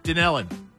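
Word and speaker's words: Dunellen: It's in Dunnell. I think that's how you pronounce it. – Dunellin. You tell her. – Dunellin.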